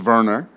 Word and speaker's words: Werner: You will have to think of CBDCs, I wrote that wrong, as a control system or permit system, not a currency, Professor Werner explained, or it's pronounced Werner.